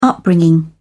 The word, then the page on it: upbringing: British English